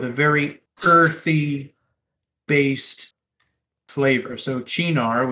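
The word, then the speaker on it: Cynar: You might also hear it pronounced as Sinar, but apparently Roukan has been schooled on this.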